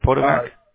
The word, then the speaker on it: Potomac: Okay, and you're in the city of Potomac. Is that how you pronounce it, Potomac? – Potomac, yeah.